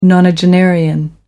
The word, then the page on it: nonagenarian: American English